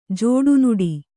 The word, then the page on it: ಜಡಿನುಡಿ: Kannada